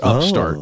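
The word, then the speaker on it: Up(st)art: It is spelled UP, parentheses, ST, end parentheses, capital ART. So Upstart, right? But it's Upstart. Oh. That's how you would say it.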